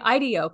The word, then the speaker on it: IDEO: I am saying, because I always wanted to say, I want to say IDEO because it makes me think of ideas when I hear the word IDEO. – Yes. – Okay. – Yes. – Yeah, so IDEO I am saying it right.